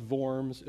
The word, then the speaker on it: Worms: So it'd be pronounced Worms. It looks to us by worms. It looks like you're eating worms, but it's actually the assembly at Worms.